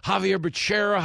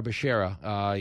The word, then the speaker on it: Becerra: No shout-outs to Javier Baccaria, as Joe Biden once called him. Javier Becerra, Javier Baccaria. Remember that whole segment where he couldn't pronounce his name? But Javier Becerra, your HHS secretary, says there hasn't been a decision yet on abortion clinics on federal land.